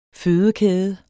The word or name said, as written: fødekæde